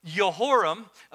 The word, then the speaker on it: Jehoram: 2 Chronicles 21, verse 20 says this, Jehoram, okay, in that time, the J would be pronounced as a Y, okay? So say Y.